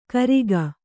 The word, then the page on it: ಕರಿಗ: Kannada